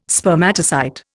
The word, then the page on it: Spermatocyte: Indian